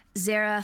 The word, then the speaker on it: Zehra: Zehra Fazal.